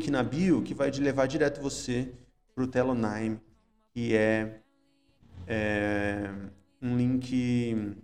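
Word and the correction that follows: Tellonym: Telo... Naime. Não é Nime de nome.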